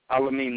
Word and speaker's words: Al-Amin: Al-Amin Muhammad. – Spell that first name for me, please. – A-L-A-M-I-N.